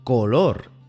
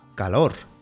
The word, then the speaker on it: color: En español, una O siempre se pronuncia O. Por ejemplo, color se debe pronunciar así, color, y nunca calor.